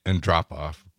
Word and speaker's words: Andropov: Andropov.